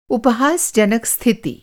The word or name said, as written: उपहासजनक